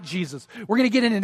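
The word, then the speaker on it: Jesús: By the way, he pronounces his name Jesus.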